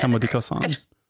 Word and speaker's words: Comme des Garçons: I think it's Comme des Garçons is how you say Comme des Garçons.